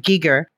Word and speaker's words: Giger: So of course, first thing I fell in love with was, I'm going to pronounce it the Swiss way, Giger, is his artwork.